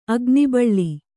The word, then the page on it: ಅಗ್ನಿಬಳ್ಳಿ: agnibaḷḷi